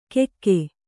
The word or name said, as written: ಕೆಕ್ಕೆ